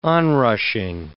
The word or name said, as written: onrushing